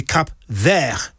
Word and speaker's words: Cap Vert: So the word for green, because Cabo Verde means Cape Green, it would be Cap Vert, V-E-R-T. You don't pronounce the T, though, unless there's an E after it.